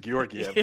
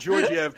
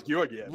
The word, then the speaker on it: Georgiev: his name is Georgiev. Yeah. You call him Georgiev because that's how it's spelled, but you've also been wrong the entire time.